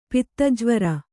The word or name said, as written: ಪಿತ್ತಜ್ವರ